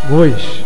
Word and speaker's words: gois: O primeiro deles, gois, G-O-I-S, com a pronúncia do O fechado, como o da palavra bois. O segundo plural é goles, G-O-L-E-S, também com O fechado.